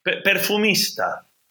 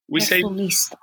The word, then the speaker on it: perfumista: I don't know, how do you say it in Spanish? Perfumerie? I mean, that sounds French to me. – Perfumista. – Perfumista. – Perfumista, yeah.